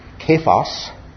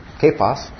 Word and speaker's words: Cephas: So this Cephas, and it's actually in the original pronounced Kephas, okay? Kephas, was the Aramaic equivalent of Peter.